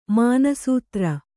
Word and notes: ಮಾನಸದೇರ: mānasadēra

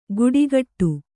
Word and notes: ಕುಡಿಗಟ್ಟು: Kannada

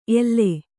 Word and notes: ಎಲ್ಲೆ: elle; Kannada

